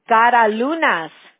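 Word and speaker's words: Karaliunas: K-A-R-L-A? – A-L-I-U-N-A-S. – Okay. Got it. Okay, so it's K-A-R-L-I. – A-L-I. – Oh, Caralunas. – Yeah. – K-A-R-A. Gara and then L-I-U-N-A-S, right? – Yeah, yeah.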